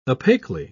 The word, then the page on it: opaquely: British English